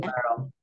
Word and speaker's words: Malheiro: Malheiro. That's the English pronunciation. – Ernestina. – Otherwise, it's Portuguese, but I'll spare everyone the Portuguese pronunciation.